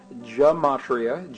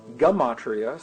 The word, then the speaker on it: Gematria: This ancient science, and it is a science, of applying numbers to find the deeper meaning in the texts, it is called Gematria, G-E-M-A-T-R-I-A, sometimes pronounced Gematria, sometimes pronounced Gematria.